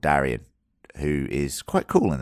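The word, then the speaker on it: Daeron: Darren. It's Darian. – Darren. – Darren. Darian. Okay, Darian is better. The way I should pronounce it.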